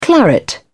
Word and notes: claret: British English

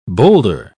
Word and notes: bowlder: British English

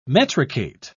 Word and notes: metricate: British English

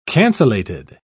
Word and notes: cancellated: British English

